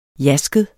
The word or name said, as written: jasket